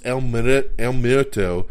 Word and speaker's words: El Muerto: This time starring Bad Bunny, and he's going to do El Miedo. I knew I was going to butcher that, even though I know the word in my head. Saying it out loud is something else. El Muerto.